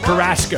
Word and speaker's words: Carrasco: Is it U or A? – Carrasco. – Carrasco. – Carrasco. – They're like, look, we're going to pronounce it like you're from Jersey. Carrasco. But it's probably like Carrasco. He's probably Puerto Rican.